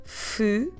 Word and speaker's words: Fevereiro: Fevereiro.